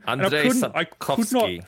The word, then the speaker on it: Andrzej Sapkowski: Andrzej Sapkowski.